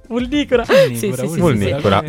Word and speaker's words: Vulnicura: E scrive, adesso sbaglio la pronuncia, Vulnicura. – Vulnicura.